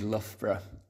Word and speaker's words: Loughborough: And what do you do when you need a break from Loughborough? Am I pronouncing that correctly? – You are indeed Loughborough. That's right.